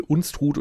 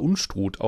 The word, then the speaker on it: Unstrut: Oder mein Lieblingsbeispiel Unstrut oder Unstrut. Auch da gibt es, je nachdem, wo du da entlang wohnst, unterschiedliche Varianten.